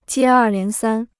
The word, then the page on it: 接二连三: jiē èr lián sān